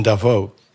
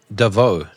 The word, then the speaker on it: Davos: More importantly, I love how you said Davos. Sounds fancy. With a silent S. – Yeah. – Like, I'm common, dude. I'm from Africa. I just say Davos.